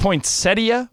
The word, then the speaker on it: poinsettia: And isn't it a poinsettia? Aren't you guys leaving a syllable out there? – We call it Noche Buena.